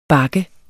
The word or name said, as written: bak